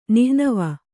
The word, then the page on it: ನಿಹ್ನವ: nihnava